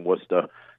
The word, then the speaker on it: Worcester: Also, as a Massachusetts person, you're obviously mispronouncing Worcester. You're pronouncing the R. That is verboten.